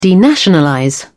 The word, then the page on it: denationalize: British English